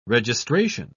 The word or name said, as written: registration